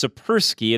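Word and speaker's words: Tsipursky: Dr. Gleb, it's Sipursky, and it's the T is silent. It's T-S-I-P-U-R-S-K-Y, CEO of Disaster Avoidance Experts.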